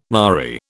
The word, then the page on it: nary: British English